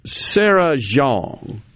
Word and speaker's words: Jeong: How about Sarah Jeong? Did I say that right? J-E-O-N-G.